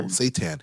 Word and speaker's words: seitan: And for plant-based folks, they can have the Mojo Seishin. Did I say that right? Seishin Bowl? – Seitan. – It's not satanic. It's a seitan bowl.